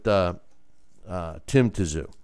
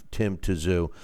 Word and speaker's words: Tszyu: And Giup is correcting my pronunciation of Tim Tszyu. And he's, you know, it's, I say, I know, I he's saying zoo, you know.